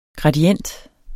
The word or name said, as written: gradient